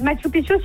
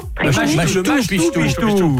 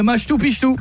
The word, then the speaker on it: Machu Picchu: Oui, c'est Machu Picchu, surtout. – Machu Picchu. Prononce bien, s'il te plaît. On dit, et voilà, le Machu Picchu.